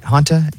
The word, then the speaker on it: Hanta: That's how you say it? – Hantus? – Yeah. Hanta. H-A-N-T-A. – Oh, I thought there was an S.